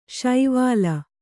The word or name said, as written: ಶೈವಲಿ